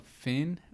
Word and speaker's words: phin: It's called Finn, or I don't know if that's how you say it.